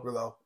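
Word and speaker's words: Rouleau: Is it Rulo? How do you say that? Duncan. Last name. – I think Rulo. – Rulo.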